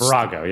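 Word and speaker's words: Farrago: Is it Farago? I never know how to pronounce Farago.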